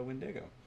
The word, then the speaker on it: Wendigo: That's a really interesting cartoon picture of a Wendigo. Very cool. Winnebago. Rhymes with it.